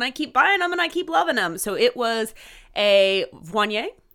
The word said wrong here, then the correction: Viognier: Is that how you pronounce it? – Viognier. – Viognier.